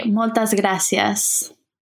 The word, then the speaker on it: moltes gràcies: I would say moltes gràcies. – Moltes gràcies. – Yeah, I think it changes a bit the accent from Spanish, but it sounds very similar. – Okay. – Moltes gràcies.